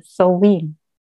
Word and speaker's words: Samhain: Und ähm, es wird tatsächlich Samhain geschrieben, wie du es auch ausgesprochen hast ähm, es ist eigentlich auch egal, wie man es letztlich ausspricht, aber ähm, die korrekte Aussprache wäre Sowin.